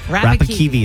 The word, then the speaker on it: Rapakivi: R-A-P-A-K-E-V-Y. – Wrong. Rapakivi is pronounced R-A-P-A-K-I-V-I.